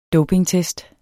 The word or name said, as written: dopingtest